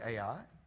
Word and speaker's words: Ai: Now, as they came to Ai, which some people pronounce I, but we'll say Ai,